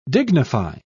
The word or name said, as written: dignify